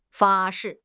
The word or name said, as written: 发誓